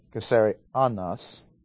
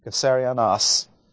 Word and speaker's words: Caesarionos: Caesarionos. And the accent is on the last syllable. Caesarionos.